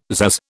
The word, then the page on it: pzazz: British English